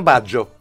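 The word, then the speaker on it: Baggio: Το ξέρεις ότι στην ουσία δεν προφέρει το γιώτα. – Εντάξει. – Αλλά αν το ακούσω από Ιταλό. – Στον Άγγελο προφέρεται. – Στον Άγγελο, ναι. – Ναι, Μπάτζιο είναι ο άνθρωπος, έτσι. Στο Ρομπέρτο είναι Μπάτζο. – Μπάτζο. – Μπάτζο. – Ναι, ναι. Το τρώνε το γιώτα.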